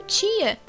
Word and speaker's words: Tchia: Com gameplay foi o Tia. que no caso se escreve T-C-H-I-A.